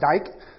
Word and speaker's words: Dike: It actually comes from a Greek goddess, dyke. D-I-K-E. I had to look up the pronunciation, and that's how it's pronounced.